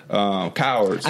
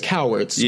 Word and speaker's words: Cowherd: Cowards. – I think it's Cowards, yeah.